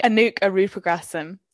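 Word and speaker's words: Anuk Arudpragasam: We are reading A Passage North by Anouk Arupagrasam. Listen to a podcast, that's how you say it.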